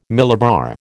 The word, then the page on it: millibar: American English